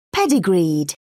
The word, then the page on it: pedigreed: British English